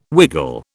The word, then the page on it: wiggle: British English